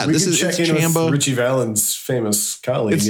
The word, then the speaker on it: Chumbawamba: It's not. It's like La Bamba. – Chumba. – It's a soft A.